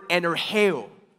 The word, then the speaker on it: energeo: energeo. Energeo, which sounds like the word that we get what? Energy from, right?